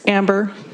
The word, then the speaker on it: Amber: Yes, Amber, A-M-B-E-R, Malinak, M-A-L-I-N-A-K.